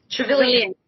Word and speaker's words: Trevelyan: Trevelyan. – Sorry, I'm butchering it. Trevelyan. Trevelyan. Okay, I got it.